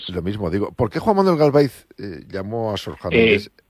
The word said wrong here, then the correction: Galaviz: Sí, antes que nada, una corrección. Es Juan Manuel Galaviz Herrera. – Disculpa, Galaviz. Digo la pronunciación. – Galaviz, sí. – Galaviz, sí. Bien.